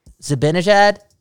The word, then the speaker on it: Zibanejad: If you want to talk about bad trades, Derek Brassard for Mika Zibanejad, right? – Yeah. – At the time, it didn't even make sense. – Yeah, I wasn't a fan of it at the time, dude, at all. – Zibanejad, I'm saying that right? – Yeah.